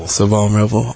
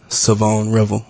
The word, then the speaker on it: Shavon Revel: All right, so everybody, dating back to when you committed, has been pronouncing it as Shavon Revel. And that's, obviously that's how it's spelled, but that's not the correct pronunciation. So it is Shavon Revel. – Shavon Revel.